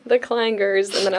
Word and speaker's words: Kleingers: I think we've had Kleiningers, Klingers, Klingers, you name it. It doesn't look like it's that hard to pronounce, but it is the Kleingers Group.